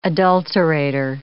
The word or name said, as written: adulterator